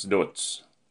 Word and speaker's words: Dodds: Namnet stavas Dodds, men uttalas Dodds.